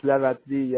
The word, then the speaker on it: Lavaltrie: How do you pronounce that? Lavalotry? – It's Lavalotry, yeah. It's a French name.